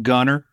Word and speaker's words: Gunter: We don't pronounce our T's. I'm Gunter, basically.